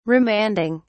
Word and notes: remanding: British English

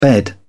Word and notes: bed: British English

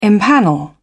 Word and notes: empanel: British English

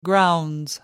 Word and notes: grounds: British English

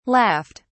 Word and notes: laughed: British English

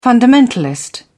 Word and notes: fundamentalist: British English

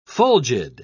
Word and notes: fulgid: British English

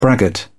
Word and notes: braggart: British English

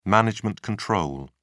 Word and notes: management control: British English

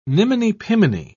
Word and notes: niminy-piminy: British English

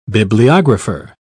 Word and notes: bibliographer: British English

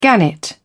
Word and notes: gannet: British English

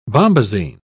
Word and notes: bombasine: British English

